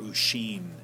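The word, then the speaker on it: Oisin: So his first long poem, The Wanderings of Ocean, or Ushin is the way he pronounced it earlier in his life, is about this figure who is led by the nose through three enchanted islands, allegorical dreams, vain gaiety, vain battle, vain repose.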